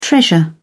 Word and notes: treasure: British English

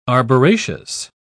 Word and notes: arboraceous: British English

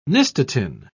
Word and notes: nystatin: British English

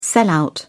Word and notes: sellout: British English

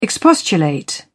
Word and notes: expostulate: British English

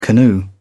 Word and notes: canoe: British English

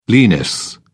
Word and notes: lenis: British English

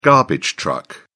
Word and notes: garbage truck: British English

